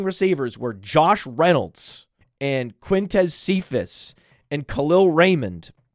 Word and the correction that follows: Kalif Raymond: or Kalif Raymond, Kalif Raymond, I apologize to Kalif Raymond and his family for mispronouncing his name there.